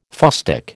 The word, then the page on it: fustic: American English